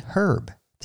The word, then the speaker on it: herb: They added an H to the front, so now we spell it H-E-R-B. Now, if we were to travel to Britain and part of the Commonwealth nations, our English-speaking cousins would pronounce, usually, the H, the herb. They would say herb. And I've watched a lot of British gardening shows, and every time that word comes up, they say herb.